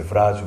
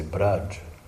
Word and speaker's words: Braj: Because in Bengal we pronounce V, we pronounce B. – Na Bengala, os Vs pronunciamos os Bs. – We don't say Vrindavan, we say Brindavan. – Dizemos Brindavan em vez de Brindavan. – So we don't say Vraj, we say Braj.